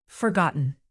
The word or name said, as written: forgotten